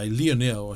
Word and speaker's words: Lionel: Well, 98, after the World Cup, Lionel Charbonnier, Lionel, I should say, if I'm using Lionel, the correct French pronunciation,